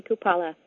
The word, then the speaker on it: Kupala: Hello, my name is Holly Kupala. It rhymes with Impala. Sometimes I tell people, pretend I'm Italian, Holly Kupala.